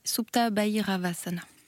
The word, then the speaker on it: Supta Bhairavasana: On va se diriger dans un instant vers Supta Bhairavasana. Je ne sais pas si je le prononce bien. Oui, Supta Bhairavasana.